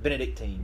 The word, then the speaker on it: Benedictine: 42-35 over Benedictine. I think I'm saying that right. I like saying that. – Benedictine.